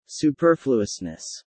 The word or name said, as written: superfluousness